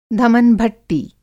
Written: धमनभट्टी

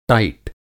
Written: टाइट

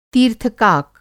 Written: तीर्थकाक

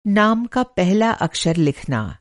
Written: अक्षर

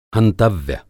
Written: हंतव्य

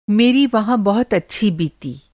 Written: अच्छा

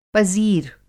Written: पज़ीर

